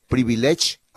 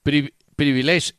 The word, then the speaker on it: Privilege: Entre otras cosas, usted ha ido a esa discoteca que llama Privilegio en español y que se pronuncia ya Privilege. ¿Ha ido? – Privilege.